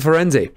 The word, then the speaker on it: Firenze: they have their first lesson with Firenze, and we are going Firenze, because that, we have confirmation from a real-life Italian, that that is indeed the pronunciation.